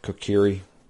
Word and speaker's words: Kokiri: Kokiri.